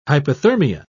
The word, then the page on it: hyperthermia: British English